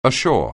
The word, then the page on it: ashore: anglais